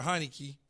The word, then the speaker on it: Heinicke: They probably don't want to upset the apple cart when they got a young man like Tyler Heinicke. Did I say it right? – Yeah. Taylor. Yes. Yeah. – Taylor Heinicke. I always want to say Heinicke.